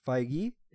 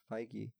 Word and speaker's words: Feige: But Kevin Feige. – Excuse me? – That's how you pronounce it. Feige. Feige. – How do you spell that? – F-E-I-G-E.